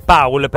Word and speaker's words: Paul: Paul, secondo me. – Paul, perché Paul, è l'accento tedesco.